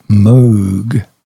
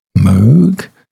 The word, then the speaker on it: Moog: In 1934, we had the birth of Robert Moog. Moog? It's spelled M-O-O-G.